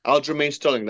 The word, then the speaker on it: Aljamain Sterling: Aljamain Sterling. That's how you say his name, if I remember right.